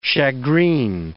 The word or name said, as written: shagreen